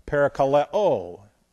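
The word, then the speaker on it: Parakaleo (παρακαλέω): P-A-R-A-K-L-E-S-I-S. Paraklesis. And that's the Greek for that. That's just transliterated. Paraklesis. And then you have parakaleo. P-A-R-A-K-A-L. And then we have E and long O on the end. Parakaleo. And so you have, notice both of them have the P-A-R-A beginning.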